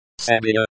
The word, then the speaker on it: себя: Себя, the E is pronounced like the E in bed. The ya is pronounced like ya in yard. The be is pronounced like the b in boy.